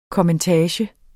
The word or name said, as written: kommentage